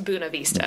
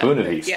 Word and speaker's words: Buena Vista: So check out A&R Barbecue if you're ever down in Buena Vista, pronounced Buena Vista. – Buena Vista.